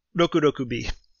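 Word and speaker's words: Rokurokubi: And for example, you got the, I'm going to pronounce it badly, the Rokurakubi, the long-necked woman. Mm-hmm. How do I pronounce that? I'm sorry, I done a bad job. – Rokurakubi. – Yeah, yeah, I butchered it.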